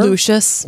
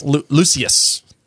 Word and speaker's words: Lucius: That's how they say it in the film. I don't know. Lucius. – Okay. – Well, that's how Voldemort says it, so I'm going to go with that. – Because he has a snake lisp. – Hey, don't lisp shame. – I'm not. I'm saying Voldemort says his name wrong.